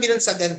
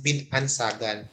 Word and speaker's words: Binansagan: So parang it's a term na medyo parang kung ikukumpara natin is parang malakas, parang binansagan din, parang in honor ka or in honor, ayan, something like that. So pero ang pronunciation namin is Bin Ansagan, pero binansagan siya sa Bisaya, pero sa Diwatahanon way Abian way is Bin Ansagan.